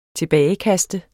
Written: tilbagekaste

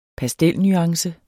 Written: pastelnuance

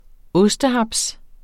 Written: ostehaps®

